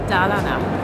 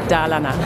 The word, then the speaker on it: Dalarna: Dalarna. – Gut, also wir merken uns auf der ersten Silbe. – Genau, Dalarna, das stimmt.